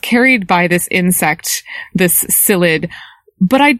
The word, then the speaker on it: psyllid: It's transmitted to the trees by an insect, the Asian citrus psyllid. Psyllid starts with a P. The P is silent.